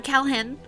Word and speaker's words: Kalhen: And he's like, I'm Calhen, which is spelled K-A-L-H-E-N. – Calhen.